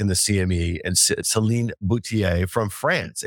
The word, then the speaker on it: Boutier: You, me, Danny Moses, with Celine Boutier. Yeah. Did I say that right, Danny? – Yeah.